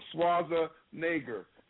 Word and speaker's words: Schwarzenegger: Is about Arnold Schwarzenegger.